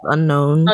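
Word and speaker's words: Unown: It's spelled, like, unknown without the K. He has the ability to possess. It's pronounced unknown.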